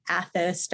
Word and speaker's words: Athos: He is the founder of the Athos Diet. Did I pronounce that right? – That's right. Perfect.